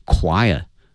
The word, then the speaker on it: choir: 这个演出呢，它的一个意义呢在于 featuring 哈，它呢是庆祝 the Shanghai Disabled People Choir 啊，那么说上海残疾人合唱团，这个合唱团它的拼写是 C-H-O-I-R，它的发音是 choir， choir 啊，它不是按那个 C-H 啊传统的一个发音的方式来念啊，它是发 k 这个音哈 choir。